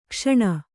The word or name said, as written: ಘೋಸನೆ